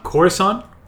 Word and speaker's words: Coruscant: Gosh, and the first phrase is Corsican. – Okay. – Did it pronounce Coruscant? – Is it Coruscant? Coruscant, capital city of the system.